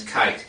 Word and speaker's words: Kite: My last name's Kite, K-I-T-E, and everybody always asks me how I spell it.